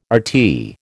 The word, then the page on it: RT: American English